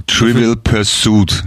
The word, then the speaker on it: Trivial Pursuit: Und sie Trivial, werden mich alle Trivial, Trivial Pursuit, ja? Betonung auf dem U auf dem zweiten.